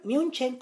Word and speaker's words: München: Espero que les haya gustado el episodio anterior sobre la masacre de Múnich, o München como se pronuncia,